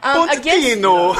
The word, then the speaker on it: Pochettino: Pochettino.